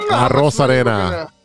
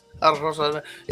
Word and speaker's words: Arozarena: Arozarena. – Oh, there you go. – Arozarena.